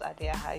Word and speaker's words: Adeha: My username is Adeha. I know please, you cannot even pronounce that. I've heard rubbish, like lots of Adeha. It's pronounced Adeha. It means first daughter, because I'm the first daughter.